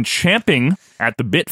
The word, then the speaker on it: champing at the bit: And Quinn, I know you've been champing at the bit for this. – Yeah, that was the right way to say it.